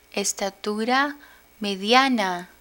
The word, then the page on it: estatura mediana: Spanish